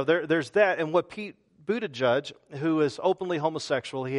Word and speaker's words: Buttigieg: I saw a little a Facebook clip where our Secretary of Transportation, Pete Buttigieg, I think that's how you pronounce his name. That's not how it looks, but it's Pete Buttigieg.